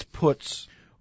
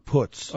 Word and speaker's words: Putz: It's Putz. – Well, it's spelled Putz. – I understand, but he went out of his way to say that my name is pronounced Putz.